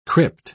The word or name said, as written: crypt